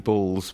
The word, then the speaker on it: Balls: Yeah, well, it's Andrew Balls, but like my friends call me Ballsy.